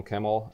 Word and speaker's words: Kemell: So, and what I've been very impressed with on Kemmel, I'm doing the North American pronunciation there, but yeah, Kemmel. – Kemmel. Okay, just so I know Kemmel, for the future. – Yeah, that is the right one.